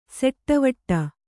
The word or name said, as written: ಸೆಟ್ಟವಟ್ಟ